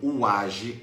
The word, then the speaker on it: Waji: vai pegar uma colher de um produto chamado Uage. Uage se escreve W-A-J-I.